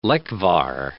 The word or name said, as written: lekvar